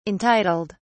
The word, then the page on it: entitled: British English